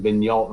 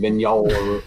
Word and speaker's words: Vigneault: And then everybody was talking about how this dude, Alain Vigneault, am I pronouncing that right? – I don't know. – Coach's last name, Vigneault. – Vigneault. Yeah. Sounds right to me.